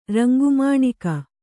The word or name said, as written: ರಂಗುಮಾಣಿಕ